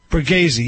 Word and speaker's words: Borghese: Am I pronouncing it right? – Borghese, yes. – Borghese. – That's right.